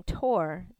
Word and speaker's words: Thor: Never mind that pronounces his last name Tor, the H is silent.